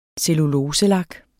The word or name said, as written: celluloselak